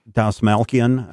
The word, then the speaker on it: Dastmalchian: David Desmalchain. Is that how you say his name? – Dasmalkian.